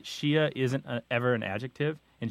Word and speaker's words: Shia: You're only going to call it Shia. That's the proper way to say it. That's S-H-I-A. Sometimes there's a diacritic mark in there to indicate that there's a glottal stop, but usually that's left out in English.